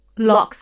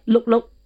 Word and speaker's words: 六: 六岁 is pronounced as L-O-K. – Lok. Lok. – Lok. So. – Like, look, look. – No. No. Lok.